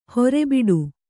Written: ಹೊರೆಬಿಡು

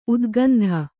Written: ಉದ್ಗಂಧ

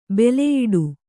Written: ಬೆಲೆಯಿಡು

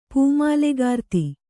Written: ಪೂಮಾಲೆಗಾರ್ತಿ

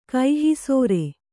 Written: ಕಹಿಸೋರೆ

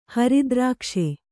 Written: ಹರಿದ್ರಾಕ್ಷೆ

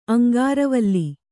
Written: ಅಂಗಾರವಲ್ಲಿ

